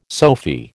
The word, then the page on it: Sophy: American English